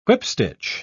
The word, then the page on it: whipstitch: British English